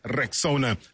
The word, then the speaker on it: Rexona: It is pronounced Rexona.